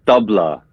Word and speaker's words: tabla: So tabla, is it tabla or tabla? – It's the tabla.